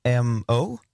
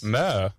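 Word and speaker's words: MØ: Final Song MO. Jag vet inte hur det uttalas. Mö. Är det uttalas på riktigt mö? – Ja.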